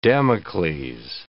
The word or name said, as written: Damocles